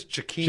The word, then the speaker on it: Jakeem: I mean, what they did, the game plan-wise, Albert Wilson, Jakeem Grant, Kenny Stills... Got speed. Did I say his name wrong? – I think it's Jakeem. – Jakeem, it might be. I gave it the soft J.